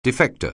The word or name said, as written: defector